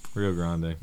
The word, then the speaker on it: Rio Grande: Rio Grande. Rio Grande Games. I say that right.